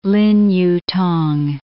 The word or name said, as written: Lin Yu-t'ang